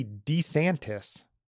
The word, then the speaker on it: DeSantis: Kind of grabbed a lot of the headlines, and that was Florida Governor Ron, it's actually DeSantis, I think. If you listen to his ad, he says Ron DeSantis, not DeSantis. We've been saying it wrong all this time.